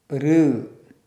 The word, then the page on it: ṛ: Sanskrit